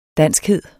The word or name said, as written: danskhed